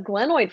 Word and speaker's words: glenoid: As we know, it was a glenoid fracture.